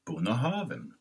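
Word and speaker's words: Bunnahabhain: Man uttalar det Bonahaven.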